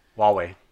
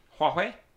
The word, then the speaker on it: Huawei: Huawei. – Huawei? – Huawei.